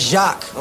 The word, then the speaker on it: Jacques: My name is Jacques, okay? It's a soft J.